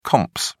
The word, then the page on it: comps: British English